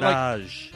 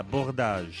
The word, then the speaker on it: Bourdages: Yeah, Bourdages. – Bourdages. – Bourdages. – Because I always just say Bourdages. – Yeah, well, I say Bourdages too, but it's in French, it's Bourdages.